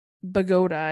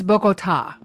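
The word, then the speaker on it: Bogotá: She's from Bogota, I thought is how you say it, but in this episode, Colombia. – Yes. – It's Bogota. – Bogota. I did not know that.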